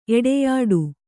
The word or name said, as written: ಎಡೆಯಾಡು